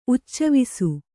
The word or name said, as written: ಉಚ್ಚವಿಸು